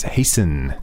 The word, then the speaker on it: hasten: It's spelled H-A-S-T-E-N. But we don't say the T, do we? – We don't.